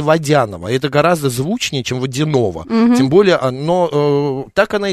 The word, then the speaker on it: Водянова: Но когда она начала карьеру международную, она стала выходить на подиум, и ее стали ударить на «я». У нас всегда в русском языке «я» под ударением, и «ё» как. И Водянова. Она боролась, она говорила, я не Водянова, я Водянова и так далее.